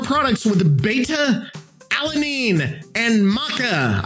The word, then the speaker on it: maca: It's pronounced maca.